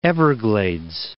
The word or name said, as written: Port Everglades